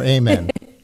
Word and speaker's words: amen: So your book, The Amen Effect, well, first important question, do you say amen or amen? – I say amen. That's the Hebrew the Hebrew way of pronouncing the word.